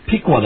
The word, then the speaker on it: Piqua: I don't live in Piqua, no. – Piqua, that's how you pronounce it.